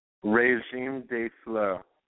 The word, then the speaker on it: Régime de Flux: How do I pronounce it? – Régime de Flux. – Perfect. That's what I said. And you know what that is? – No, you said de Flux.